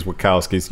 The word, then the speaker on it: Wachowskis: I never remember how their name's pronounced. – Oh, It's Wachowskis.